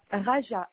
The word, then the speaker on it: Rajaa: Raja, R-A-J-A-A.